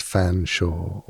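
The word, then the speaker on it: Featherstonehaugh: where a name like Featherstone Shaw is pronounced Fanshawe, or Cholmondeley is pronounced Chumley, or Magdalene is pronounced Maudlin, and Worcester is pronounced Worcester.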